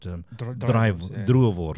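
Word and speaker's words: droëwors: Whereas boerewors, not boerewors, but druivors.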